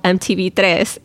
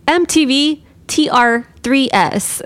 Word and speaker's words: MTV Tres: Remember he was doing pickup lines and the channel was called MTV Tres and he's like MTV TR3S and that's how he was saying the pickup line and we're like that's not how you say it.